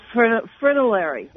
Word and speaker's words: fritillary: A golf fritillary. How do you say that? – I can't say it either. Fritillary. There we go.